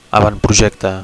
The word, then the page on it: avantprojecte: Catalan